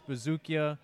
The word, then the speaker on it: Buzukja: All right, so we're here with Louis Vuitton and Gucci model, Dennis Bazookia, on Instagram and Twitter, at Dennis Bazookia, B-U-Z-U-K-J-A, pronounced Bazookia.